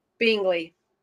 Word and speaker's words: Binggeli: You say your last name because I don't want to screw it up. But it's Bingley. – I would not have said it that way. – I know. Nobody does.